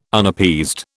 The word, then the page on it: Unappeased: British English